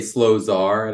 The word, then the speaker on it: Slosar: People say Slosar, and I'm like, no, it's slow, not fast, sir, not man.